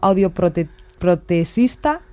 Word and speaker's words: audioprotesista: Descerrando mitos, se lo hemos preguntado a Alicia porque ayer ofreciste una charla, eres, lo decías antes, óptica optometrista y a ver si lo digo bien, lo tenía escrito audioprotesista. – Eso es.